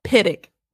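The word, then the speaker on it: Pittock: He said it was like a Pittick. D. Pittick.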